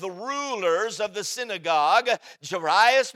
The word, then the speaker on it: Jairus: I pronounce his name Jairus. When you preach, you pronounce it however you want to.